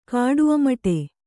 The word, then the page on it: ಕಾಡುಅಮಟೆ: kāḍu amaṭe